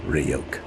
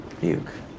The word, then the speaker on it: Ryuk: Hey, kid! It's pronounced Ryuk. – The demon sits on the bed. – Ryuk.